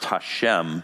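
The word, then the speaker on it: Hashem (השם): Instead, they substitute something for it in Hebrew that is pronounced Hashem, and it means literally the name.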